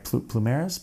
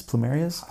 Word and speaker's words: plumerias: And then these are I can never say it right. plumeras, plumerias.